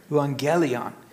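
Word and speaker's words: euangelion (εὐαγγέλιον): So if I remember what he said correctly, it's pronounced euangelion. Oh, sorry, no, euangelion. Sorry, see, I can't even do it right. Euangelion, which literally means good news.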